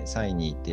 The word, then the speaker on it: CiNii: CINII、うんでサイニーって読むんですけど、